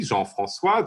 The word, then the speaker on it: Jean François: Uh, so, you pronounced my name in French correctly, Jean Francois.